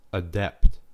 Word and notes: adept: American English

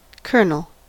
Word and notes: colonel: American English

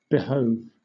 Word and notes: behove: British English

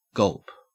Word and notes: gulp: Australian English

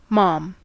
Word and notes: mom: American English

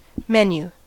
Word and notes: menu: American English

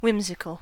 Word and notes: whimsical: American English

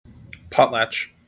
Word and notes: potlatch: American English

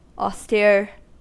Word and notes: austere: American English

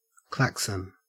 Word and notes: klaxon: Australian English

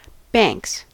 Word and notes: banks: American English

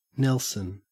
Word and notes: nelson: Australian English

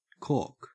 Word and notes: cork: Australian English

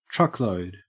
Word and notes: truckload: Australian English

